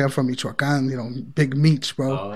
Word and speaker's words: Big Meech: Is it pronounced Big Mitch or Big Meech? – Big Meech. Big Meech, yeah.